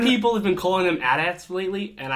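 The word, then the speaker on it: AT-AT: I mean, you see AT-ATs, which, side note, that is the correct pronunciation.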